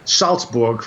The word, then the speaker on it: Salzburg: Salzburg, for example. In German, you don't put in a T. The Z is pronounced TZ. Salzburg is S-A-L-Z-B-U-R-G, not S-A-L-T-Z-B-U-R-G.